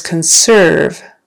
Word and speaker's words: conserve: All right, the word is conserve. Accent on the second syllable.